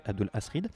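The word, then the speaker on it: Abdul Alhazred: Il y parlait d'un livre terrifiant, sans vraiment détailler son contenu, le Necronomicon, et de son auteur, Abdul Asrid, prononcé Abdul Asrid, l'arabe fou, dément, disait-il, dévoré vivant par un monstre invisible en 738 dans une rue de Damas.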